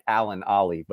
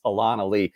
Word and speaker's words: Alan Ali: It looks like Alan Ali, but it's Alon Ali.